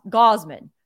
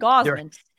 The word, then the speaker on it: Gausman: Kevin Ga- Gossman, Goss- Gausman. There's three ten ways to pronounce this name. Kevin Gausman.